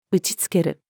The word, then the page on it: 打ち付ける: female